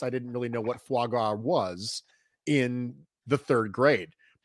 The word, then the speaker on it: foie gras: But I need somebody in the comments section to settle this unexpected dispute that we just popped up on as to whether or not it's pronounced foie gras, or how did you pronounce it, Nate? – Foie gras. – Foie gras. It's foie. – You are correct.